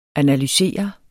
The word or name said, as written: analysere